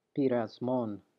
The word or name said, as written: pirasmon